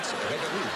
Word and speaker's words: Rippyroux: R-I-P-P-Y-R-O-U-X. Ripperoux.